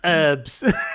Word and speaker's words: herbs: It's I, I don't know why people miss the first letter. – It's pronounced herbs.